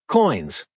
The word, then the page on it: coins: American English